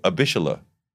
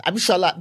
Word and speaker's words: Abishola: So that's why I picked But how often do you get Abishola? – But that is kind of how it's pronounced. Abishola, that's how it's pronounced.